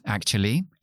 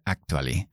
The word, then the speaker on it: actually: Actually, en realidad, pues igual que en castellano, lo importante aquí es la pronunciación, porque todo el mundo dice actually, pero es actually actually, actually.